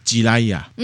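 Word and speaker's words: ジライヤ: 文乐，嗯嗯，好，就是三个人操纵一个娃娃，那个自来也日文念成呢吉来亚，嗯，也有一个写法叫做俄雷也了哈。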